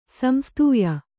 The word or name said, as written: ಸಂಸ್ತೂಯ